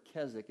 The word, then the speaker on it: Keswick: That word Keswick, it's pronounced Keswick. It's actually a city in England.